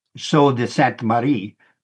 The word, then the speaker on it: Sault Ste. Marie: And Sault Ste. Marie, by the way, try to pronounce it the way the French would have pronounced it, Sault de Sainte Marie, which the French word S-A-U-L-T, at least in Old French, meant falls or rapids.